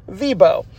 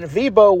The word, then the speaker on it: Viborg: It looks like Viborg, but it turns out it's pronounced Vibo.